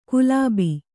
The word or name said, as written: ಗುಲಾಬಿ